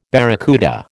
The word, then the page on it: barracuda: American English